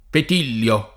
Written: Petillio

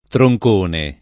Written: troncone

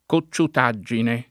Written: cocciutaggine

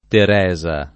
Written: Teresa

